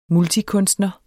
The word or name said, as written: multikunstner